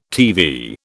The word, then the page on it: TV: British English